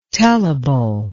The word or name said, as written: tellable